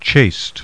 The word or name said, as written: CHASTE